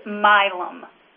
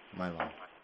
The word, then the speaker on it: Milam: Before we get started, Before we get started, I just want to, for our caller's sake, make sure that we understand that DASD Milam is pronounced Milam. – Milam.